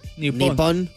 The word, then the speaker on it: Nippon: ¿Cómo se dice Japón en japonés? – Japón. – Creo que ellos gritan nipón. – Nippon. – Soy nippon.